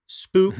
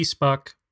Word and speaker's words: Spuk: I want to talk about a release on that's currently on Future Fonts it's Spook, mm-hmm. maybe Spuck. It's spelled S P U K. Spuck doesn't sound right, so we're going to go with Spook.